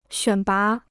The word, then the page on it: 选拔: xuǎn bá